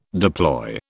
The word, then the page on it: deploy: British English